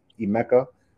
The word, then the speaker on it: Emeka: Even Google, wherever you want to go, just type in my full name, which is Emeka. That's E-M-E-K-A.